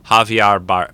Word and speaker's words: Javier: Javier, or Javier, that's how you say it, Javier. You don't say the J in Spanish. Javier Bardem, is that how you say his last name?